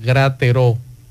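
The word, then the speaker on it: Gratereaux: Su nombre es Alba Lidia Gotró de Rosario. Se escribe Grateró. Así que, eh, digo, es Gratero, perdón, no Gotró, Grateró.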